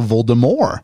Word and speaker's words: Voldemort: J.K. Rowling previously stated the T in Voldemort is silent, as is in the French word for death. So Voldemort, which means flight from death.